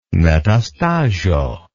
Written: metastasio

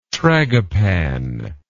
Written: tragopan

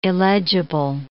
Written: illegible

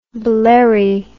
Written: blerry

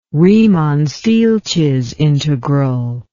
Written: riemann stieltjes integral

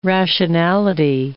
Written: rationality

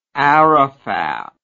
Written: arafat

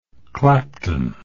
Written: clapton

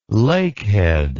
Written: lakehead

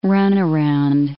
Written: runaround

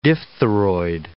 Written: diphtheroid

